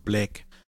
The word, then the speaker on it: Black: Mit dem Code BLACK. Auf Supplements, auf E-Books, auf Online-Seminare. – What? – B-L-A-C-K. – Ja, okay. Aber es war gut, dass du es nochmal buchstabierst, weil du hast es mit E ausgesprochen.